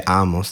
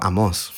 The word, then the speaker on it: Amos: Sai, Amos, il profeta Amos, si immaginava un fiume, E tra l'altro eh, invece di dire Amos diceva Amos.